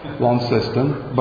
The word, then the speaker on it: Launceston: We have a congregation in Launceston, or Launceston is obviously the English pronunciation, but that never took in Australia. It's too subtle, so Launceston. But most people in Australia can't say that correctly either, so the C throws people off.